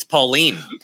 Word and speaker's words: Pauline: No, no one says Pauline. – Napoleon. – No one says. – I would say. – Yeah. Well, you're wrong. – It's Pauline. – Pauline. – Okay. – But how do you spell it? – P-A-U-L-I-N-E. – That's Pauline.